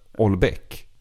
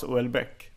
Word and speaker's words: Houellebecq: Jag har hört någon som sa det ska uttalas Uelbeck.